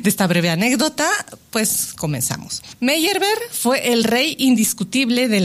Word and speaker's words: Meyerbeer: Pero bueno, como anécdota, Meyerbeer lo decimos en México porque se parece mucho a la pronunciación en francés, pero si lo decimos en alemán sería Meyerbeer.